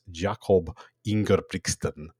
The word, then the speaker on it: Jakob Ingebrigtsen: He's second all time for that age behind Norway's Jakob Ingebrigtsen.